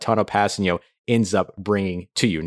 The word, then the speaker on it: Kpassagnon: So Passanio is the way that his last name is pronounced.